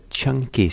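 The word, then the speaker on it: Caṅkī: S-U-T-T-A, and it's called the Chanki Sutta, and it's spelled usually in English C-A-N-K-I, and it's number 95 in the Middle Length Discourses.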